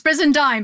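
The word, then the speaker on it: Brizendine: Dr. Luann Bresendien. Have I said that right? – That's good. It's Bresendien, but we're good.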